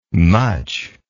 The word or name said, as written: mutch